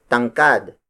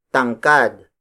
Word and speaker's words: tangkad: Kisame, the Tagalog word for ceiling, is spelled as K-I-S-A-M-E. Again, that's baba and mababa. For human height, we use tangkad. Tangkad. Tangkad. Tangkad is spelled as T-A-N-G-K-A-D.